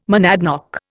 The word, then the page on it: monadnock: Indian